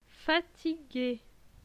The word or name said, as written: Fatigue